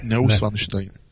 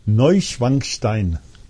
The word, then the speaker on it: Neuschwanstein: Tady ten v podstatě zámek, nebo tady to ústřední sídlo Wittelsbachů by se mělo jmenovat Neuschwanstein. – Ne. Neuschwanstein. Ne. Neuschwanstein. – Jo, tak Neuschwanstein. – Neuschwanstein, no, je to hrozný.